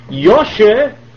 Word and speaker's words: Yoshe: Yoshe was the Lithuanian way of pronouncing Yosef. Yoshef. So it became Yoshe, with the F drop. And Ber is the German Yiddish translation for Dov, which is a bear, B-E-A-R.